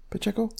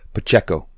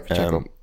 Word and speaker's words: Pacheco: Would you pronounce it like that? Pacheco. – Pacheco. Pacheco. You're throwing an N in there. – Oh, sorry, Pacheco.